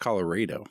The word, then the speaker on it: Colorado: It's pronounced Colorado. – It's Colorado. Colorado River.